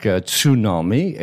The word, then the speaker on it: tsunami: In the English language, certain words, you have a silent sound, like tsunami. It is simply the silent T.